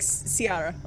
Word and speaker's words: Ciara: Spelled like Kiara? – Like Ciara. Like C-I-A-R-A.